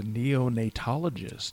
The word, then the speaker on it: neonatologist: And I probably don't even know how to pronounce it, but he's a neonatologist.